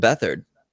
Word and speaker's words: Beathard: Go get yourself some Beathard. – Or if you phonetically spell his or say his name, it's Beat Hard.